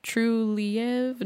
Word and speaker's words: Trulieve: So there was another article about Trulieve's, the CEO's husband, and of course Trulieve, I might be saying it wrong, Trulieve, no, I think it's Trulieve, like believe, but with true in front of it.